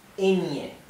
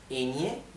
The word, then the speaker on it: ñe: Instead of pronouncing ñ, if you're having a hard time with this, you can say ñe, n-i-e, okay, ñe, ñe, e-n-i-e. This is not exactly the right thing, but it's the most similar to the right thing, okay, ñe. Once you manage to pronounce ñe, you can try to pronounce ñe, ñe, okay?